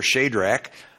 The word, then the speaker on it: Shadrach: or it's a long A in your King James there, Shadrach, Mishael, according to the pronunciatory gazetteer, and Meshach.